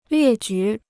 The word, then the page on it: 列举: liè jǔ